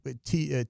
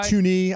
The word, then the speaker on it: Thuney: And though, is it Tooney? Is I think I say it right, Tooney. – T-H-U-N-E-Y. – Yeah, Tooney.